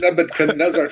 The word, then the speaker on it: Nebuchadnezzar: I know it's got a D in there, but you don't have to pronounce the D. Nebuchadnezzar. No. Just say it fast.